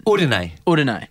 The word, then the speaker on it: Udine: How do I pronounce that? – Udine. Yeah. – Tell me one short story about how basketball is different in Udine.